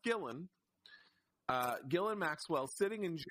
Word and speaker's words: Ghislaine: It's not Ghislaine. It's pronounced Gillen.